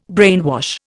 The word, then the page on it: brainwash: Indian